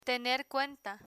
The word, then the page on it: tener cuenta: spanish